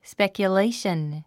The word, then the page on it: speculation: spèkjuléiʃən; スペキュレイション